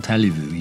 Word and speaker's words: allez-vous: Comment allez-vous? You put the T on the allez-vous, but you don't pronounce the Z.